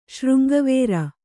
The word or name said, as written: ಶೃಂಗವೇರ